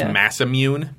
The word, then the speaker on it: Masamune: It's pronounced Masamune.